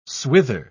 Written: swither

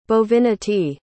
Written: bovinity

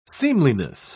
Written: seemliness

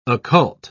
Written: occult

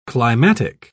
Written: climatic

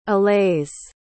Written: allays

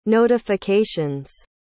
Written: notifications

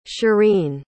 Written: chereen

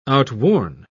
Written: outworn

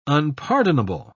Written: unpardonable

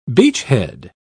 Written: beachhead

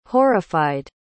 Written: horrified

